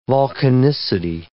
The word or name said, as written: volcanicity